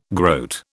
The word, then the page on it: groat: British English